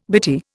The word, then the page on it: bitty: Indian